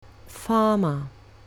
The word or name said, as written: farmer